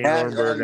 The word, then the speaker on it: Rodenburg: Shane Rodenburg.